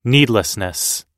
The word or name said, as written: needlessness